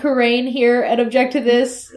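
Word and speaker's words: Khura'in: They are forcing now Kurain, which is more accurate for how the Japanese pronunciation is. But they put an apostrophe in it now, so it's K-H-U-R apostrophe U-R-A apostrophe I-N.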